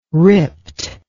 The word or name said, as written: ripped